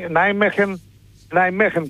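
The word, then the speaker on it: Nijmegen: Τώρα το Νάιμεχεν, ξέρεις, έτσι προφέρεται. Κάπως έτσι, Νάιμεχεν. – Ναι, οι Ολλανδοί έτσι το λένε, Νάιμεχεν.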